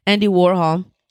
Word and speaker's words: Warhol: yeah, Andy Warhol.